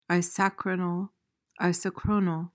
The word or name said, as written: isochronal